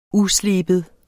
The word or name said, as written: usleben